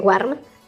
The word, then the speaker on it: warm: Gusano, worm, o bien worm, se pronuncia con una especie de E, y tibio, que se escribe warm, se pronuncia con una especie de O.